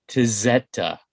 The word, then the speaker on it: Tezeta: And I'm probably going to mangle the title here of the band name, but the album is called Tezeta. T-E-Z-E-T-A.